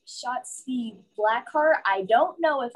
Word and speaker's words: Shotzi: Yelling Yahtzee, but Shotzi.